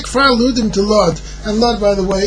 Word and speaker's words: Lod: Rav Lezer, the city of Lod, is actually the proper way to pronounce it. Rav Lezer lived in the city of Lod, and he says a person who brings a get from Kfar Ludim to Lod, he must say Bifanei Nechtam, Bifanei Nechtam.